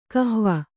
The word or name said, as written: ಕಹ್ವ